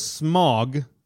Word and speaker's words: Smaug: how does Smaug, that's the correct pronunciation.